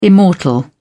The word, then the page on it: immortal: British English